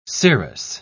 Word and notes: scirrhus: British English

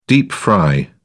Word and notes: deep-fry: British English

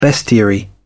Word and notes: bestiary: British English

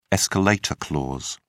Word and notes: escalator clause: British English

